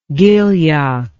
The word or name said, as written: Gilyak